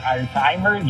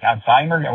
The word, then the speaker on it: Alzheimer: Y la otra, Alzheimer, yo decía Alzheimer. Pero con eso, no pronuncié la Z, y sí, Alzheimer, ojalá que nunca me pegue eso, porque.